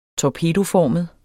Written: torpedoformet

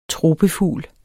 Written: tropefugl

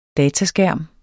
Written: dataskærm

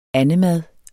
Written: andemad

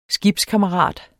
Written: skibskammerat

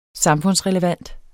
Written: samfundsrelevant

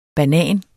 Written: Bananer

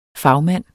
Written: fagmand

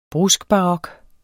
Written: bruskbarok

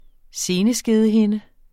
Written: seneskedehinde

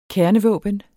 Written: kernevåben

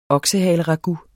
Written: oksehaleragout

